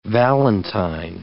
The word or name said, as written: valentine